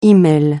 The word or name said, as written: e-mail